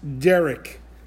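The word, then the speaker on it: Derek (דרך): It's pronounced Derek, like the proper name that sometimes a man will have.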